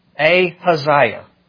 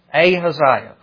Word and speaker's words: Ahaziah: It's a long A, ha, long Isaiah. Ahaziah. Ahaziah. That's how you pronounce it. Ahaziah. Kind of like Uzziah, but Ahaziah.